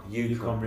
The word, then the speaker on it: Yukon: Like the, is it the is it pronounced it the Yukon Yukon Yukon, Yukon, River, Yukon River yeah.